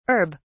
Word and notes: herb(s): British English

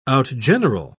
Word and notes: outgeneral: British English

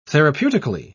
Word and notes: therapeutically: British English

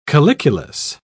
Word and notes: calyculus: British English

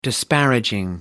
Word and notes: disparaging: American English

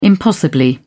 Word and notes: impossibly: British English

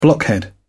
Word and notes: blockhead: British English

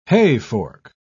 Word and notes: hayfork: British English

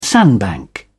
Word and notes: sandbank: British English

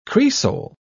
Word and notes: cresol: British English

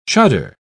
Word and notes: chuddar: British English

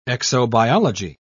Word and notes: exobiology: British English

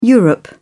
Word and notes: europe: British English

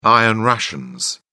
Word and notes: iron rations: British English

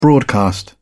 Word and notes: broadcast: British English

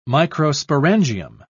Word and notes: microsporangium: British English

